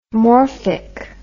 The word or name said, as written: morphic